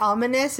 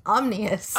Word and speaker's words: ominous: That's my go-to word when I can't pronounce things, is the fact that ominous is not pronounced omnious.